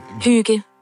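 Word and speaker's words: hygge: Una delle più note è, per esempio, quella danese, che di solito viene detta Igge, A-H-Y-G-G-E, invece si dice, si pronuncia... – Igge. – Sentite. – Igge.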